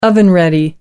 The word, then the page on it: oven ready: American English